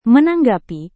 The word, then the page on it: menanggapi: Indonesian